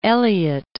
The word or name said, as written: Eliot